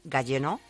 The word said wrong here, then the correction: Gaggenau: Gaggenau. – Ah, Gaggenau, vale.